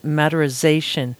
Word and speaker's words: maderisation: And just for listeners, just if you're wondering what this word is, matterization, I'm going to spell it for you. It's MADERISATION. So there's no Z in there.